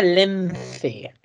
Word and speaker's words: Lence: totalmente español y se pronuncia Lence. Lo que pasa es que aquí en Inglaterra me llaman Chris Lenz y yo no les discuto por no complicar la vida.